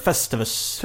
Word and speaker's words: Festivus: Ja, det stavas Festivus, men de uttalar det Festivus.